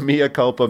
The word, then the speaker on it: mea culpa: This is kind of our mea culpa. Am I saying that right? Mea culpa? – You got it.